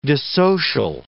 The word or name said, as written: dissocial